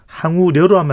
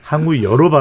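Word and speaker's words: 황우여: 비대위원장 항우여, 항우려로 하면 안 되죠. 항우여로 발음을 해야 되죠.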